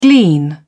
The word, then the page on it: glean: British English